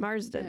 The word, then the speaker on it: Marsden: Marsden? – Oh, I love Marsden. – Marsden.